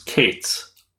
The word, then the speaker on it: K8s: Exception to this is K8S, a common abbreviation for Kubernetes, which a lot of folks pronounce Kates.